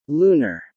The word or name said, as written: Lunar